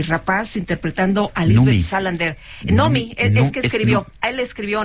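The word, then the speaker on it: Noomi: En sueco se pronuncia Nomi, Nomi Rapaz.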